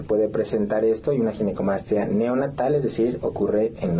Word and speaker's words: ginecomastia: Sí, exacto, es ginecomastia, ok.